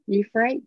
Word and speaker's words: Euphrates: Euphrates.